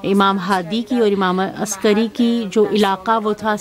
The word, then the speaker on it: عسکری: عربی میں تو گاف تلفظ ہے بھی نہیں کہ ہم امام حسن عسکری کو تلفظ عسکری کریں, عربی میں موجود نہیں ہے.